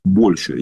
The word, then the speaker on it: большая: Да, у людей скопилось огромное количество, действительно скопились большие суммы, но, во-первых, они касаются, вот, скажем так, да, большая часть, с большой буквы О, с ударением на О, большая